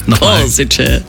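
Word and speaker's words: Košice: 2 neue EC-Züge fahren künftig von Wien bis ins schöne Corsice. – Oh, das hast du auch sehr schön ausgesprochen. – Corsice.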